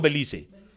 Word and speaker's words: Belice: ¿Cómo se dice? ¿Bélice o Belice? Belice, Belice.